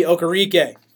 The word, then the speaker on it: Okereke: You bring in Bobby Okereke.